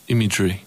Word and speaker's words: Imagery: Jak go wymawiasz dokładnie? – Imagery. – Imagery, okej, okay, Imagery.